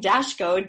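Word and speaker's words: Daszko: So if people can spell that last name, Dashko, D-A-S-Z is in zebra, K-O, that's the challenge, they can find me, and I do the, you know, podcast.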